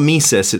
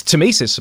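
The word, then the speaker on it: tmesis: And it's not a silent T. It's not mesis. It's just... – No, it's temesis, apparently.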